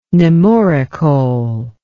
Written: nemoricole

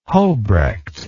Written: hobrecht

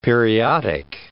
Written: periodic

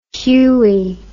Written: huie

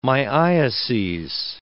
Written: myiases

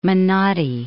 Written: Menotti

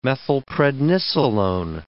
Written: methylprednisolone

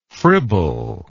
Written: fribbler

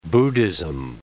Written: Buddhism

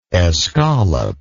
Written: escallop

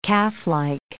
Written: calflike

